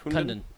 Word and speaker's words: Kundun: Kundun. – Oh, I do want to check that out. – So do I. – Yeah. – K-U-N-D-U-N. Did I say that right? – Yeah, Kundun.